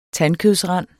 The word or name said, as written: tandkødsrand